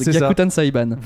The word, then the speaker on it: Gyakuten Saiban: Gyakuten Saiban. – Voilà, j'ai jamais réussi. Gyakuten Saiban. Gyakuten Saiban 3.